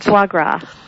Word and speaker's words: foie gras: There's a campaign against the production of veal and against the production of foie gras. Is that how you pronounce it? – Foie gras. Mm-hmm.